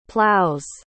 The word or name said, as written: plows